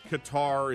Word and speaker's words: Qatar: Is it Qatar? Qatar? I can never pronounce it. I've always said Qatar. I guess Qatar is a acceptable pronunciation.